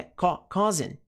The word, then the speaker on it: causing: Importante, pronúncia, não é causing, é causing.